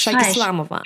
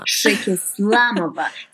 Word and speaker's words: Shayk-Islamova: Pass auf, es ist Shayk-Islamova. – Shayk-Islamova. So richtig betont, Silbe für Silbe.